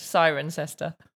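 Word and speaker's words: Cirencester: How would you say that one? – Sirencester. – Oh, so close. Sirencester. – Sirencester, okay.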